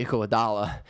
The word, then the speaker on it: Iguodala: Andre Iguodala. – Yep. – Yes, so we had some good years in there. They weren't great, but they were, like, I think at a time with Iguodala, they were, like, the worst team in Philadelphia.